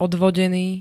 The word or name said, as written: odvodený